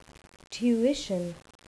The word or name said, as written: tuition